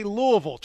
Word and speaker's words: Louisville: you say it. We say Louisville. Try one more time. – Louisville. Louisville. Louisville. Louisville. Louisville. Yep.